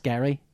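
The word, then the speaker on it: Gerry: A creator-owned book called Dead Rabbit, written by Jerry Duggan, who is well-known for his work on... – It's actually pronounced Gary.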